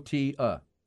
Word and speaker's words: Cotija: Cotija. So Cotija. Cotija.